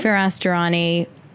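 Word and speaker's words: Firas Dirani: Is that how you say his name? – Firas Durrani.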